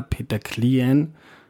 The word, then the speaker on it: Peter Klien: wenn mir jetzt da Peter Klien, habe ich es jetzt richtig ausgesprochen? – Ja. Das ging ja nicht besser.